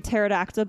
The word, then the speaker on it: pterodactyl: So it is impossible to hear a pterodactyl going to the bathroom because the P is silent.